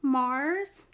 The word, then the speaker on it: Marzz: The song is called Countless Times by Marz.